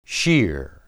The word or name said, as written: shir